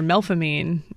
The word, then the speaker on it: Melpomene: In English, we pronounce it Melpomene normally, but here in the city, it's pronounced either Melpomene or Melfamine. There is no H, but some people do pronounce it as though there were an H following that P, and some people are convinced that it was spelled that way at some point.